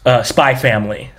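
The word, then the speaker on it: Spy x Family: your Spy Family. I learned that you're not supposed to pronounce the X.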